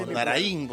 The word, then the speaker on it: Amondaraín: Amondaraín, porque hay acento en la I.